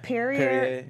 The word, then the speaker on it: Perrier: And then Perrier. I don't know how to pronounce anything today. How do you pronounce that? – Perrier. Yeah.